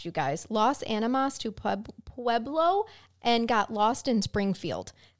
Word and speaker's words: Pueblo: My, it is Pueblo.